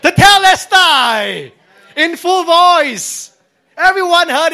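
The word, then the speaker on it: Tetelestai (τετέλεσται): Tetelestai. It is the correct pronunciation because I asked Google.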